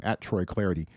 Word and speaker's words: Clardy: Give me a follow, too, @TroyClarity. Last name is spelled C-L-A-R-D-Y.